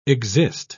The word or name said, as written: exist